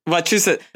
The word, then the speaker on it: Wachusett: I did Tim Johnson's Wachusett. Am I saying it right? – Wachusett. – I always want to put more syllables in there to make it even more Massachusettsy.